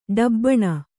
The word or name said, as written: ಡಬಾಣ